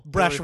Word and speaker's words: Brzechwa: Oh, how would I pronounce the name Brzechwa? B-R-Z-E-C-H-W-A? – Brzechwa. – Okay, just sneeze a little. – Brzechwa really is what I'm going to say.